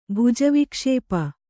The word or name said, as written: ಭುಜವಿಕ್ಷೇಪ